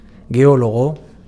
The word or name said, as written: geologo